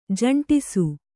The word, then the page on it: ಜಂಟಿಸು: Kannada